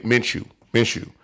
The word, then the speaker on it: Minshew: The Jacksonville Jaguars benching, I don't know how to say his name, Minshew, and putting Foles in. I'm saying that right now. That was the worst decision that they made. Minshew.